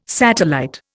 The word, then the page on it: satellite: Indian